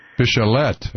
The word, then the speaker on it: Bachelet: I heard her name pronounced Michelle Bachelet. – Bachelet. All right.